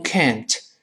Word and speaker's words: can't: 你说能读成 can，不能 can't，读快了 t 没了，究竟能不能嘛？